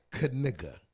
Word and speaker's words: Knigga: Say it right. – Knigga. – Gotta say that K. – Knigga. Noah Knigga. – This ain't like the pneumonia where the P is silent. Knife where the K is silent. – It is Noah Knigga.